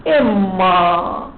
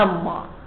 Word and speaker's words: إما: إما تلفظ عادة بكسر الهمزة والتشديد، لكن أحيانا نفس إما يتلفظوها أما، هي إما اللي تأتي للتنويع، إما زيد وإما عمرو. أحيانا البعض يقول أما زيد وأما عمرو، لاحظت شلون؟ هي نفس إما لكن أحيانا تقرأ أما.